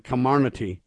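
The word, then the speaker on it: Comirnaty: Comarnaty is the way they want to say it. But all I'm telling you right now is it sounds like a con game to me. Not a Comarnaty. Comarnaty.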